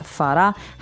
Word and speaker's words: farà: So the accent is on the last syllable of that, farà.